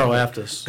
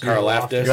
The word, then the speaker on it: Karlaftis: Karlaftis. – Karlaftis.